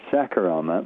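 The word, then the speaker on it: Shaker Aamer: Well, first of all, how do I say his name right? And then please tell us all about him. – All right, you're not too far off. It's Shaker Amer.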